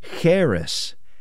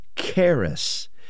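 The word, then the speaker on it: charis (χάρις): Instead of the Hebrew pronunciation, this is the word charis, charis, and it is graciousness. Now, you have most likely heard that grace is the unmerited favor of God. And that comes from this same word, this charis.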